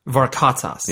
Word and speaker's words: Varkatzas: Varkatzas. – Yeah. – Really? – Yeah, Varkatzas. – Spell it. – V-A-R-K-A-T, Z as in zoo, A S as in Sam.